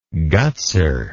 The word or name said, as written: gutser